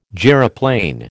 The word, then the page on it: gyroplane: American English